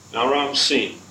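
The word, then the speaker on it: Naram-Sin: Naram Sin. I'm pronouncing S-I-N as Sin in order to keep you from thinking of it as the word sin, which is not in style anymore for most of us.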